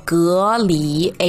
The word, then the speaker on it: 蛤蜊: 那真正的读音呢？我确认又确认了，叫做隔离。哎，没错，就是那个隔离的隔离，哈哈。